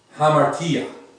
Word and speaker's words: hamartia: Hamartia is how that seems to be pronounced.